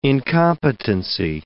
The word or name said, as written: incompetency